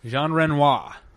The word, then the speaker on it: Jean Renoir: Jean Renoir. – Feeling a little silly, are you? – No, that's how you say his name.